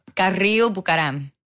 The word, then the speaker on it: Carrillo-Bucaram: I want to say your name, but I can't say it still. So it's Kristina Carrillo-Bucaram. – And I'll say a white boy version, Carrillo-Bucaram. – You did great.